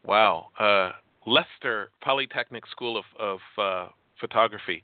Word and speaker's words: Leicester: Keep going. Eventually you'll get to Leicester.